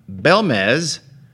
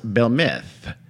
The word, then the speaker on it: Belmez: If you look it up on Google, it should be pronounced Belmith. But I'm not going to do that because it sounds like I'm making fun of them. It's not that close to Barcelona. I'm sorry, Barcelona. – Well, so Barcelona is not the only population that pronounces the S in that manner. – I'm going to anglicize it and call it Belmez. – Belmez. – It's a Z, by the way. B-E-L-M-E-Z.